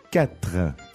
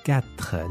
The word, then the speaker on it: quatre: Now, when you pronounce it carefully, it sounds like two syllables, right? Quatre. – Yeah. – Okay. But when you say it fast, it's like quatre. – Quatre. – Yeah, it doesn't sound like two syllables anymore. – Mm-hmm. Just one syllable, quatre. But when you're being careful, quatre. Okay.